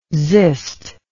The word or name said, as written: xyst